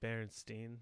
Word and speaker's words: Berenstain: But then it come to find out it's Berenstain, which I don't know, maybe it still is pronounced Berenstain. But it's stain, like STAIN. And I did 100% think it was Steen, ST, like kind of a Jewish inspired, like Berenstain, you know?